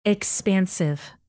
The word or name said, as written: expansive